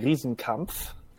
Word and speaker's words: Riesenkampf: So, the German way of pronouncing it would be Riesenkampf.